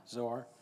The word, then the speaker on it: Zoar: Am I saying that right? – Uh-huh, Zoar.